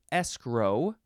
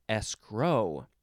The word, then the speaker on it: escrow: Next is escrow. You can also emphasize the second syllable, escrow.